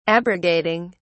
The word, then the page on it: abrogating: British English